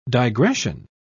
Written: digression

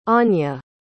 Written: ania

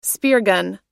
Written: speargun

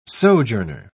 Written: sojourner

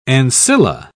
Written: ancilla

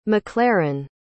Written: MacLaren

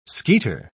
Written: skeeter

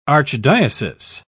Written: archdiocese

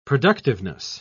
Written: productiveness

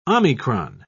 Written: omicron

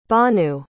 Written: bhanu

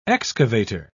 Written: excavator

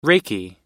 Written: reiki

